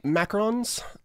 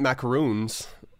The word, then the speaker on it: macaron: She sent us some macarons, the little French. – Is that how you pronounce it? – Macaron. – The French president? – Not macaroons, which are like the coconut cookie things or whatever.